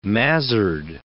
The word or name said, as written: mazard